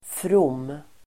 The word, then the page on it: from: [from:]